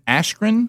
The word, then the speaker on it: Askren: And he's taking on Ben Askren.